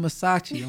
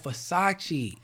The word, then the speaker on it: Versace: Got my daughter mispronouncing a designer to my I want some Masachi. I'm like, Masachi? I think she talking about some food. I want some Masachi. I'm like, Masachi? I'm off Versace.